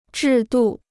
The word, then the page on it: 制度: zhì dù